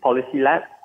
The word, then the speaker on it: PolisiLab: It's Policy Lab, but that's spelled PolisiLab.